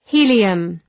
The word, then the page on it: helium: ‘hi:lıəm